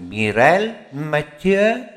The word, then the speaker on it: Mireille Mathieu: Caruso, e lei si chiama, nome francese, molto pratico col francese, Mireille Mathieu, detto bene.